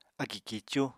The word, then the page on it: akì kíicú: apatani